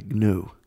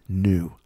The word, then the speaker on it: gnu: You only lack the antlers, which would actually, honestly, put some antlers on you, you would look like a gnu. A gnu, a wild beast. A gnu? – Gnu. I think it's actually pronounced new.